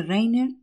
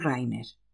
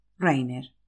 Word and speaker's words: Rainer: Ya le he dicho que mi nombre no se pronuncia Rainer, sino Rainer.